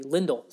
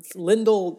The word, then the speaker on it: Lindell: You've got Jordy Benn with Steven Johns, and you have John Klingberg with Esa Lindell, which is, I've learned, is the actual pronunciation of Lindell. It's actually Lindell. That is interesting. – Yes, so I know I'll trip over that and I'll say Lindell quite a bit, but I'm going to try and Lindell like Kindle. So that's how it's pronounced.